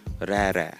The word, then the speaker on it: raerae: So you say it's spelled R-A-E, R-A-E, but it's pronounced rere.